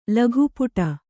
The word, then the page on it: ಲಘುಪುಟ: Kannada